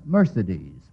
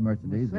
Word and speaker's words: Mercedes: Now, how about Mercedes? Mercedes. – Who loved Mercedes? – Yes. What sailor did, Mr. Kieran? – The Count of Monte Cristo. – Yes, Count of Monte Cristo. A sailor who loved Mercedes in the book of that name.